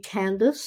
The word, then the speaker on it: Candace: And they can contact me, Candace, C-A-N-D-A-C-E. Ace, not ice, is what I like to say. – Okay. – Sometimes it's spelled with an I-C-E.